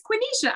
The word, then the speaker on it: Quenisha: Well, I remember you on that call, like so determined to actually say my name. She was like, it is Quenisha. I got it right.